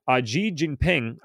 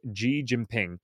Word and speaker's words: Xi Jinping: China claims Taiwan as its own territory, and its leader, Xi Jinping, I try to keep looking up how to pronounce his name, and it's amazing how many different sources you get even amongst the news. So the best I got was Xi Jinping, has focused on bringing the self-governing island democracy under the mainland's control by force if necessary.